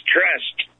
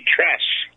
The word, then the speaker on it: Truss: She sat up and gave her last handshake to the last British Prime Minister, this Truss woman, but they keep pronouncing it as Trust on TV, Truss.